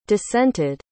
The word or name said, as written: dissented